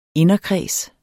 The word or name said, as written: inderkreds